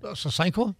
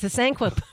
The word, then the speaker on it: Sasanqua: How do you say that? – Sasanqua? – Yes, Sasanqua bushes.